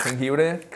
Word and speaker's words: jengibre: Oh, how do you say that one in Spanish? – Jengibre. – Jengibre? – Jengibre. – Oh, jengibre. I never knew that way.